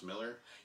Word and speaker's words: Millar: Mark Millar. We always pronounce Miller. – Yeah, exactly. That one is just a Scottish thing.